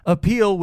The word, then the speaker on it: Apeel: Appeal, which is spelled A-P-E-E-L,